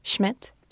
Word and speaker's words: Schmidt: It's J-A-I-M-E, Schmidt, S-C-H-M-I-D-T, 22, which is my lucky number.